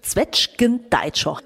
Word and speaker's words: Zwetschgendeitscher: Zwetschgendeitscher. Richtig ausgesprochen, Steffi? – Deitscher ist richtig ausgesprochen, ja.